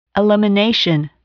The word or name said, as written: elimination